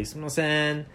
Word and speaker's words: sumimasen: But sumimasen is a word where there's a certain nuance in the way it's said. It's like a very nasally sumimasen.